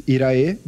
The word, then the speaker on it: Irae: And my Twitter is Irae, just my first name. It's spelled I-R-A-E.